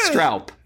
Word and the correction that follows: Stroop: How is it pronounced? – Stroop. – Oh, shit, for the jeez. Stroop. Ah, oh, man. That would have been like my sixth guess.